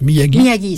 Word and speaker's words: Miyagi: Alors, la région de Miyagi, comment ça se prononce ? – Miyagi, c'est ça, oui.